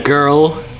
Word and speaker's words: Gurl: And The Girl, but spelled G-U-R-L. – Yeah, so girl. – The Girl. – The Girl. – And then after the girl? – And then Nozomi Nobody.